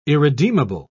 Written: irredeemable

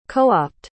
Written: coapt

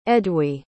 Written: edwy

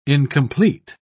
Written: incomplete

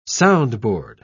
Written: soundboard

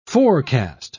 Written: forecast

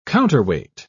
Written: counterweight

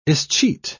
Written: escheat

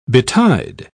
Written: betide

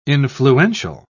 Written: influential

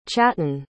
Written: chattan

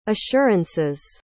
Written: assurances

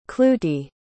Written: clootie

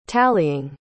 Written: tallying

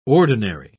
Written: ordinary

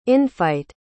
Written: infight

